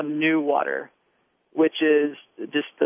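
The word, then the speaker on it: NEWater: Right, they've kind of smushed together and created a portmanteau phrase where it's as they type it out, it's capital N-E-W and then lowercase A-T-E-R, but they pronounce it new water.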